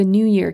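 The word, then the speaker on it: NeuYear: And it's the New Year calendar. New as it's spelled N-E-U.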